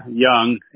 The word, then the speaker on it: Young: Steve Young. It's S-T-E-V-E. And the last name is Young. Y-O-U-N-G.